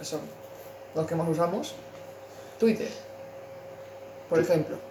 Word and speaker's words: Twitter: Twitter.